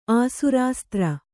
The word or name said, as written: ಆಸುರಾಸ್ತ್ರ